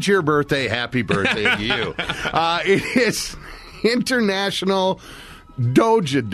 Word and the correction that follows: Doge: Day. You know, like the Doja coin? – Doge. – Doge. Yeah, D-O-H-J is how it's pronounced. Doge. Yeah, there you go. – D-O-G-E, right? Is how it's spelled, though? – D-O-G-E.